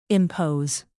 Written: impose

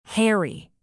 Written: hairy

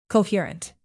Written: coherent

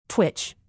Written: twitch

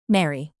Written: marry

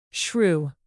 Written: shrew

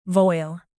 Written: voile

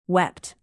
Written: wept

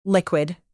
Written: liquid

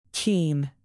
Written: keen

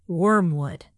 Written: wormwood

